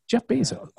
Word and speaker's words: Bezos: And we were going to ask Jonathan if he was the one building that, but probably Jeff Bezos.